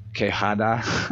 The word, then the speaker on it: Quijada: I'm sorry, I think I said it wrong the first time. Quijada. It's been a long time since I was in Spanish. I did look up a pronunciation for that to make sure I got it right, but it's been several minutes ago.